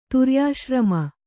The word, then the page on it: ತುರ್ಯಾಶ್ರಮ: turyāśrama